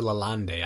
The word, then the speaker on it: Lalande: Lalande or Lalande. I don't know if it's a silent E at the end. – It's Lalande.